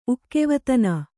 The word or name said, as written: ಉಕ್ಕೆವತನ